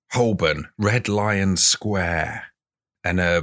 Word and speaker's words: Holborn: Also, I think it's pronounced Holborn, but carry on. Set the scene. – We're in Red Lion Square, Holborn.